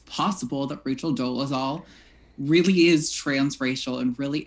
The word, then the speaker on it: Dolezal: Dolezal, let me know if I pronounced it wrong. – Dolezal.